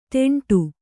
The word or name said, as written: ಡಿಂಡ